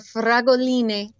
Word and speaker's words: fragoline: And these wild strawberries are fragoline, like little strawberries. – Fragolini. Excellent. Fragolini.